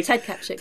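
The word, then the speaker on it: Kaptchuk: Yeah, Ted Kapchuk.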